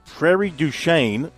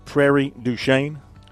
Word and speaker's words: Prairie du Chien: Any opinion on that pronunciation, Mr. Bergen? Prairie du Chien? – No one down here is going to know any difference, so we'll say you're spot on.